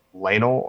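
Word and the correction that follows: Laenor: Yeah, Sir Lanor Valeria.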